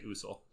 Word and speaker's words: Usul: In the movie, they call it. They say Usul.